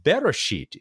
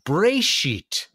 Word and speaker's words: Beresheet: Beresheet is how it would be uh, logically pronounced uh, pronounced in English, but they're saying that really in Hebrew it ought to be something more like Breshit.